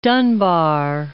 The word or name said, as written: Dunbar